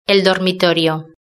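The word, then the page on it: el dormitorio: Spanish (Spain)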